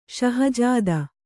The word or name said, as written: ಷಹಜಾದೆ